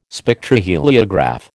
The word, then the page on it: Spectroheliograph: American English